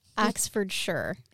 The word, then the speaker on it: Oxfordshire: Oxfordshire is actually how you say it. We say Shire over here in Americas, but it is Oxfordshire.